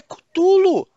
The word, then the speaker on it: Cthulhu: Em inglês é Cutulo. Cara, os caras ficam.